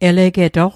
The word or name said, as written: elegetoq